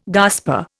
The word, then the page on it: gasper: Indian